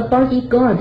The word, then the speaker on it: bodyguard: 而 bodyguard 这个 U 呢，它基本上是不发音的，所以 U A R 合起来就是 guard。最后一个单词，我们看到 wardrobe，A R 就是母音加上 R 会先画起来，O space 一字为一不发音。